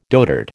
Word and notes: dotard: American English

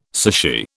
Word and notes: sushi: British English

